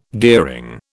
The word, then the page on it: gearing: British English